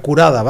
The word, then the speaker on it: Corada: El disco de Corada, lo tenéis que buscar como Corada, pero se pronuncia Curada, ¿vale?